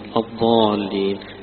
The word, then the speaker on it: الضالين: لكن لا تنطق الضاد ضاء زي ما البعض يقول لك هي هذا مخرجها هذا وتقرأ، ولا الضالين يقرأها قريبا من الضاد أو يقرأها كالضاد، هذا ليس بصواب.